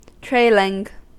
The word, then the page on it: trailing: American English